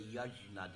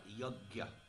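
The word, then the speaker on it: yajña: The Sanskrit word yajna, or some of you may say yajna, depending on your particular way you pronounce the word. My spiritual master taught me like a Bengali style, yajna.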